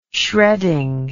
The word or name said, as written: shredding